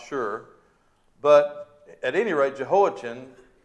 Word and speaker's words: Jehoiachin: Well, Jehoiakim's son, Jehoiachin, if you want to pronounce it chin on the end there, was a successor to his father.